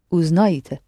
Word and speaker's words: узнаете: Yes, but you pronounce this E so short. – Узнаете. – Right. – Пожалуйста. – Узнаете. It's a short E, the same letter, but it's so short, it sounds Y, hardly heard.